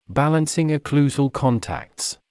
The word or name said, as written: balancing occlusal contacts